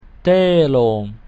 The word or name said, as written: telum